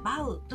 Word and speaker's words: Vow: VOW と書いてバウ